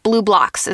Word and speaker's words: BluBlox: Blue Blocks is how it's said, but it's just spelled a little differently.